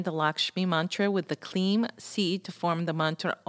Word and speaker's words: klim: So let me tell you about the seed sound kleem, K-L-I-M, pronounced K-L-E-E-M.